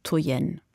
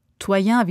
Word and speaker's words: Toyen: Toyen sprechen die Tschechen diesen Namen aus, auf Deutsch wird häufig die zweite Silbe betont, Toyen. Toyen wiederum heißt die Künstlerin in Frankreich.